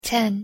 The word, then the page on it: ten: English